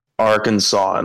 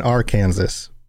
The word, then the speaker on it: Arkansas: I love that in the United States, we have a state called Kansas and Arkansas, but it's not pronounced that way.